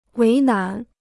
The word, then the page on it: 为难: wéi nán